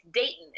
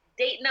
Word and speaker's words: Dayton: I am in Dayton, Ohio, which is the first time I've pronounced that middle T. It's Dayton. It's Dayton, Ohio.